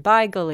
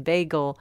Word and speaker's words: bagel: Because it originally comes from the Yiddish can be pronounced in different ways. And so according to where the Yiddish was spoken, it could have been beigel, it could have been bagel.